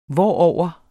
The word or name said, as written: hvorover